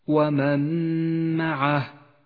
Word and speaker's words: ومن معه: ومن معه النون الساكنة وبعدها حرف الميم تدغم النون بالميم وتسقط النون من اللفظ ولذلك لونت بالرمادي في مصحف التجويد إشارة إلى عدم نطقها